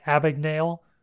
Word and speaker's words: Abagnale: And then for March, we have Catch Me If You Can by Frank Abagnale.